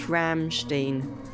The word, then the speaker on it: Rammstein: How do you pronounce Rammstein? Oh, here we go. – Rammstein. – Rammstein? No. Yeah? How do Germans pronounce Ram...